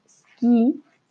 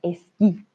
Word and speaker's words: esquí: Es por eso que pronunciamos la E de forma completamente normal, ¿vale? No decimos ski, sino ski.